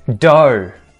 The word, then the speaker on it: dough: Dough. Dough. GH that's silent again.